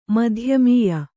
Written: ಮಧ್ಯಮೀಯ

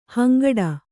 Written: ಹಿಂಗಟ್ಟು

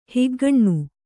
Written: ಹಿಗ್ಗಣ್ಣು

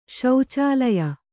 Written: ಶೌಚಾಲಯ

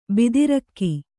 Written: ಭದ್ರಕ

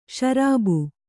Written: ಷರಾಬು

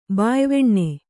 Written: ಬಾಯ್ವೆಣ್ಣೆ

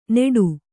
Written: ನೇಟು

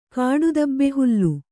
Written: ಕಾಡುದಬ್ಬೆಹುಲ್ಲು

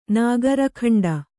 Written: ನಾಗರಖಂಡ

